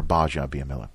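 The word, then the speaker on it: Gbaja-Biamila: We got Akbar, Gabaja, Biamila. In. – Yeah. – So the admiral. – By the way, that's it's a silent G. – It's silent. – It's Akbar Baja Biamila.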